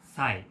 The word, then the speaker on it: Sai: My name is Sai. S-A-I.